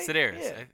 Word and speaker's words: Sedaris: Did I say that right? Yeah. – Sedaris? Is it what? Amy? – Sedaris, right? – Sedaris. – Yeah, I said it right.